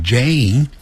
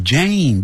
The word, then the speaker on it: Jane: 所以叫卷舌的 my little sister Jane，念成 Jane 不要念成 Jan，把 a 音 a Jane， my little sister Jane is quite good at， quite 就是相当或十分，十分好的 good， good 不要念成 good，叫 good， good 跟书本那个 book 的发音是一样的， book good 元音都是一样的啊。